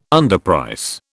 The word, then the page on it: underprice: British English